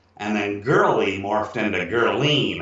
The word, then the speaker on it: Girlene: Girlene. – And what is it? It's spelled G-I-R-L-E-N-E.